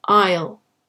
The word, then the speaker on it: aisle: Be careful with your pronunciation of aisle. It's pronounced like the contraction of I will, aisle.